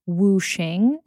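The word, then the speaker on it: wuxing (五行): And those 5 parts follow, and please correct me if I'm mispronouncing it. I know that you are from South Korea, or your family is, but this is the Chinese concept of wuxing. Is that correct? – That's correct.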